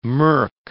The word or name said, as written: murk